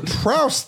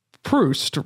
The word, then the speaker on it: Proust: Well, it's not Prouse though. – Yeah. It might be pronounced Proust, right? – Hey Google.